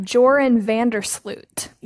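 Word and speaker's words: Joran van der Sloot: I have Joran Vandersloot. – Yes, and you pronounced it perfectly.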